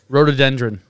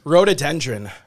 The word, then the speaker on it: rhododendron: Rhododendron. – Rhododendron.